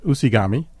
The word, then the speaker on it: UCIGAME: So that's a library that is spelled UCIGAME, but is pronounced Usigami, like origami or something like that.